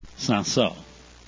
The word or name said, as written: cinsaut